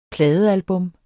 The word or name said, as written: pladealbum